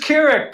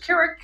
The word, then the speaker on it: Kirik: It is Kirik. Perfect pronunciation. If you listen to a blue jay singing, the bird goes, Kirik, Kirik. And that's what I'm named for.